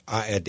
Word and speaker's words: ARD: Yeah, ARD in German.